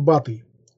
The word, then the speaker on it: Батый: Хан Батый, ударение на букву А, хан Золотой Орды, второй сын хана Джучи, внук Чингисхана, умер в 1256 году в возрасте 48 лет.